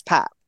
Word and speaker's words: Pape: Actually, while we're on this, and because I thought we had all agreed that it was Pap Sarr, right? Didn't he say that's how you say it is Pap? And then for a while, it seemed like loads of his teammates were calling him Pape.